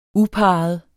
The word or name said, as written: uparret